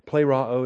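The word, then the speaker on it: plēroō: It comes later just because the paradigms are tougher, but plero is the verb. It's a long E, the eta, P-L-E-R-O-O, plero, with 87 New Testament uses.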